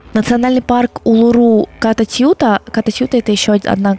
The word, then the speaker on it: Улуру: Я, кстати, не уверена, что я правильно ставлю ударение, но если Улуру я точно нашла, что на последний слог, то про Анангу я заслуживающей доверия информации не нашла, поэтому говорю, как э, говорю.